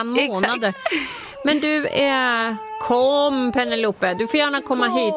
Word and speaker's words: Penelope: Åh, Penelope. Och jag bara, vet du, vi kallar henne för Poppy. Jag tyckte inte det var så för ni vill ha engelskt uttal. – Ja, Penelope.